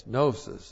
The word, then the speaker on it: gnosis: And they got their name from the Greek word gnosis. G-N-long-O-S-I-S, gnosis.